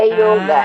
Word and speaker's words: yoga: É, é que em sânscrito, a palavra yoga, o O, quando está perto do Y ali, ele é fechado, não é aberto. Então, é, é questão de pronúncia, porque o original é em sânscrito.